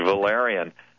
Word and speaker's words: valerian: I've never heard of valerian, if I'm even saying that right. – Yeah, no, you said it perfect, valerian.